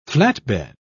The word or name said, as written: flatbed